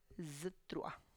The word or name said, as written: zItrua